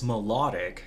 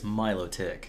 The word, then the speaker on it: Milotic: You would think it's melodic because it's beautiful and melodies, right? – Yeah. – But it's Milotic.